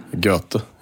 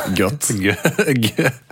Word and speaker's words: Goethe: Han der, Goethe. – Mm. Ikke Goethe. – Goethe. – Nei, Goethe. – Mm.